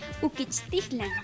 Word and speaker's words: uquichtitlán: Gallo en náhuatl se dice uquichtitlán.